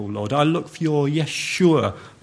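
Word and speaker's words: Yeshua (ישוע): His name of Jesus was highly appropriate when we consider Jesus' actual Hebrew name is actually pronounced Yeshua.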